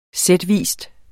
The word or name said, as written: sætvis